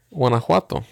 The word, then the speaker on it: Guanajuato: and ended up going to Guanajuato? Did I say that right? – No. You're like 2 miles off. – What is it? – Guanajuato.